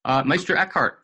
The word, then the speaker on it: Meister Eckhart: Meister Eckhart.